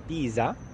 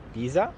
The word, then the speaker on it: Pisa: A ver, ustedes tienen que decir Pisa para decir mi ciudad. En vez, tienes que decir pizza para decir la comida. Así que es Pisa y pizza, ¿vale? – Vale, entonces Pisa o Pisa.